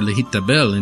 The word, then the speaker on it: lehitabel: To be baptized, or lehitabel in Hebrew, rhymes with the common word lehitpalel, or to pray.